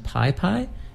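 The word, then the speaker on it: PYPY: We're going to start off with a new band based out of Montreal, which I believe is pronounced Pi Pi. It's P-Y-P-Y.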